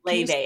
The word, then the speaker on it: Laufey: Leve, and it's spelled Laufey because she's Icelandic.